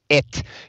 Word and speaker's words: et: And of course, the background here is that in Finnish, the ampersand sign is pronounced et, so E-T, which is the former name, sort of the Espoon Teatteri.